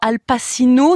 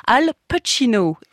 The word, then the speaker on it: Al Pacino: D'ailleurs, comment doit-on le prononcer ? Son nom, Al Pacino, non, Al Pacino, écrit Samuel Blumenfeld dans Le Monde.